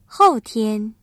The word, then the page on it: 后天: hòutiān